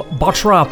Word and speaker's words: Bottrop: Before Tangerine Dream, we heard some punk rock from the Upright Citizens from Bottrop. Did yes. – I pronounce that correctly? – Yes, you pronounce it correct.